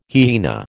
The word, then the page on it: hyaena: American English